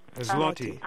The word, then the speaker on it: Zloty: And Poland is Z like zebra, L-O-T-Y. – Right, right. – How do you pronounce that? – Zloty. – I don't know. – Zloty, okay.